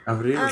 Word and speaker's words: Avril: Isn't it French for April? Avril? – Avril. – It is, yes.